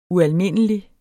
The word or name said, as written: ualmindelig